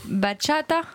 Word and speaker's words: bachata: C'est pas comme ça qu'on dit. – Comment on dit alors? – Vous êtes des prouveurs. – L'accent tonique est sur le deuxième A, je pense. – Bachata. – Ouais. Eh oui, bravo.